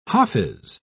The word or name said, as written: hafiz